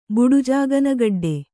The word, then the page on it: ಬುಡುಜಾಗನಗಡ್ಡೆ: Kannada